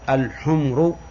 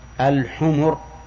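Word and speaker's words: الحُمُر: نعم، الحمر جمع حمار، ولا يصح أن تنطق بها بسكون الميم، لأنك إذا قلت الحمر فهي جمع أحمر أو حمراء، لكن يجب أن تقول الحمر.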